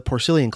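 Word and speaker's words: Porcellian: Now, if you look at the name of the club, Porcillian, while it's spelled the same, the root pork, P-O-R-C, in the Porcillian Club, which is pronounced with a soft S in that case, is a very different word root from the P-O-R-C, pronounced with a hard C, in, say, Porkfest, which I attended back in the middle of the summer.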